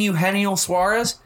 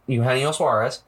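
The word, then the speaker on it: Eugenio Suárez: And Eugenio Suarez. Yes, that is how you pronounce that. Eugenio Suarez.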